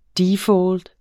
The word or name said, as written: DEFAULT